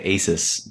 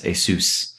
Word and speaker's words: Asus: Mine's I always want to say Asus, but I think it's actually pronounced Asus.